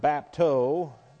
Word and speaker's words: bapto: And there's another word, bapto, B-A-P-T-O, bapto, B-A-P-T-O, and that has a connotation of dipping, which this has two elements involved in it, down and up.